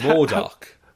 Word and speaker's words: MODOK: MODOK? Is that as... – MODOK.